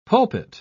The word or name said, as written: pulpit